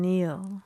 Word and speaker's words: kneel: How are you spelling Neil? – K-N-E-E-L, Neil.